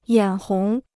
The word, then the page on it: 眼红: yǎn hóng